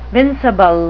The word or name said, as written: vincible